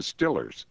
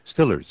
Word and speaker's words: Steelers: I don't know about that, but speaking of football teams with names that are pronounced in idiosyncratic ways, the Pittsburgh Steelers are known locally as the Stillers. – Stillers. – Stillers.